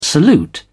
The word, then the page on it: salute: British English